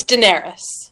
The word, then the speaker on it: Daenerys: As far as, you know, character stuff, we did get finally Jon Snow at the end of the episode told Daenerys, or do I say her name right? Daenerys, right? – It's Daenerys. – Daenerys. Okay. All right. Daenerys, my bad.